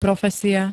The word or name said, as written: profesia